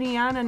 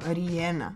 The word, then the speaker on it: Rihanna: I eto, baš sam skoro i vidjela da, eto, na primjer, Rihanna nije Rihanna, nego Rihanna.